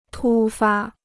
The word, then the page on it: 突发: tū fā